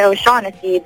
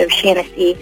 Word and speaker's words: O'Shaughnessy: He said Americans always say O'Shaughnessy, but actually it's O'Shaughnessy.